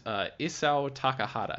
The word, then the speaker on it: Isao Takahata: HT, tell me about Isao Takahata.